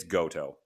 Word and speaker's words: Goto: Third match was Yoshihashi and Hiroko Goti. Wow, that was some really bad pronunciation. I apologize. Hiroko, just, it's Goto.